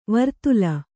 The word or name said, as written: ವರ್ತುಲ